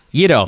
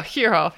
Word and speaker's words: gyro: Yiro. – Yes, there you go. Hiro.